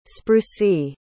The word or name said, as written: sprucy